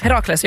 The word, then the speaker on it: Herakles: Uttalar man det så? Herakles. – Herakles, ja.